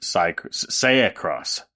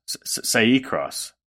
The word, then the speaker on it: Acai: um, but I would pronounce it say cross say cross. Ooh, okay. Acai yeah. Uh, and Spelled I'm like spell acai. It.